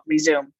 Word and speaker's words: Rezoom: Um, and for anyone who is hearing themselves in my words, I really recommend my third book, Rezoom because and it's spelled r-e-z-o-o-m like resume do it fast Rezoom it's called Rezoom the powerful reframe to end the crash and burn cycle of food addiction.